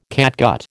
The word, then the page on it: catgut: American English